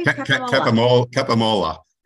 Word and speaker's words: Capomolla: Capomolla.